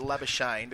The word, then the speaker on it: Labuschagne: There's going to be many ways of pronouncing his name, but we're going to go with Labuschagne because that's what he's asked for. If you can't use the Afrikaans hard R, then don't use it at all.